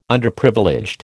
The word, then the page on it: Underprivileged: American English